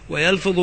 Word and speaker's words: ويلفظ: وقوله كم يلفظ، كم هنا استفهامية بمعنى أي عدد في محل الرفع مبتدأ، ومميزها محذوف تقديره كم شخصا يلفظ الإسلام، ويلفظ بفتح الياء وكسر الفاء من باب ضربة، خبر كم للاستفهامية.